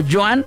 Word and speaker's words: Joan: se quedó como Joan Sebastián, sin acento en la última, ¿eh?